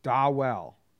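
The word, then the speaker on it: Dawel: They say it's pronounced Dawell.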